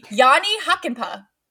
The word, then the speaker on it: Jani Hakanpää: He got traded to Anaheim for, I finally know how to say his name because I watched the game the other day, Jani Hakanpää. – There you go. – Which sounds like hockey puck, so he is Jani Hockey Puck.